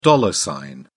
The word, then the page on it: dollar sign: British English